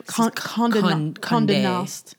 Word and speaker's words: Condé Nast: And the company, which is, I don't know if I'm even saying this right, it's Condé Nast. Mm-hmm. I know it's just an accent over the E. – I'm telling you, it's a French, Condé Nast.